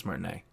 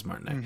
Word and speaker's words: Martinet: Martinet? I think it's Martinet. It is Martinet. Mm-hmm. Charles Martinet is no longer the voice of Mario moving forward.